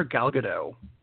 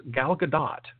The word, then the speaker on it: Gal Gadot: Because for the past 2 years now, I've been calling her Gal Gadot, which is the way it looks and sounds like a villain in a Godzilla movie. And we've been saying it wrong the whole time. It really is Gal Gadot. – Because she ain't French. – She's not French. She's Israeli. So the T is not silent, and that's going to be hard for me to do for a while because I've been doing Gal Gadot for so long.